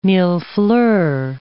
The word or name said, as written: millefleurs